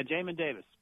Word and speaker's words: Jamin Davis: Yeah, Jamin Davis.